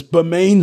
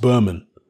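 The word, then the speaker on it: Bermane: It's Bermain Stavern, not Berman Stavern. Well, yeah, it's spelt Bermain, but Berman Stavern himself says it's pronounced Berman.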